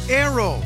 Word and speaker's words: Naoero: This program is previously called the country Nauru, N-A-U-R-U. The 11,000 residents have altered Nauru to be now Aero, N-A-O-E-R-O. They're going back to the pronunciation in their native language and losing a pronunciation.